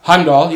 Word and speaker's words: Heimdall: Heimdall. – Heimdall, yeah.